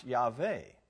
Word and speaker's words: Yahweh: That's too technical, Teresa, but that's so in the modern Hebrew, it would probably better pronounce Yahweh.